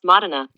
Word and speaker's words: Modena: How do you pronounce Modena? – That's pronounced Modena.